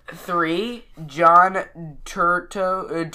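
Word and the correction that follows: Turturro: Turturo. – Can't say his name.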